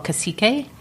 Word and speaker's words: Cacique: I can't even, it's the brand, how do you pronounce that? – I think the brand is called Cacique, I think.